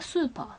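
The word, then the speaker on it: セーラームーンS: S って書くんですけどこれがスーパーって読みます。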